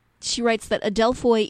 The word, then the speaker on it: Adelphoi: Well, to Jesus Adelphoi, which is a Greek word, and that's spelled A-D-E-L-P-H-O-I, in case you're curious, Adelphoi.